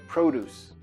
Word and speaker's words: produce: Escreve igual produzir, que é produce, mas a pronúncia é diferente, como substantivo, produce.